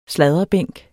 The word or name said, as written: sladrebænk